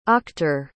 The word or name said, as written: auctor